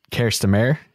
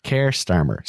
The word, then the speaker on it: Keir Starmer: So there's going to have to be a change of leadership, and that came now in the form of Keir Starmer, which when I see it written down, I want to pronounce it Kirstamer. – Kirstamer. – Kirstamer. It just sounds more pleasant. It sounds more pleasing than Keir Starmer. – Starmer. Stammer? Starmer? – Starmer. Keir Starmer. Sir Keir Starmer.